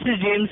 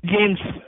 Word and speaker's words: James: My name is James, sir. – Jill? Did you say your name was Jill? – Is that Gladden? – Was it Jill? – James.